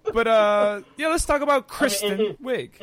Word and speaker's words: Kristen Wiig: It's spelled Kristen. So that's Kristen. – Right. Kristen Wiig. – Yeah. That's Kristen Wiig.